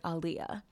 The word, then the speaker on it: Aaliyah: And I thought that it was Aaliyah, but when she pronounces it, she says that it's similar to Prince Ali, marvelous he, Ali. So it's like Aaliyah.